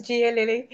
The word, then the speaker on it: Gia Lili: So as you've said, and I love the fact that you pronounce my name correctly, it is Gia Lili,